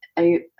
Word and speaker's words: Ayurvedic: And, you know, the more nuanced resources, you know, around like music therapy or the Ayurvedic?